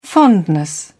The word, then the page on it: fondness: British English